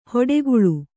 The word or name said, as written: ಹೊಡೆಗುಳು